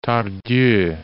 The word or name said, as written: Tardieu